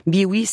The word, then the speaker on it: Bewe: Biwi s'écrit B-E-W-E.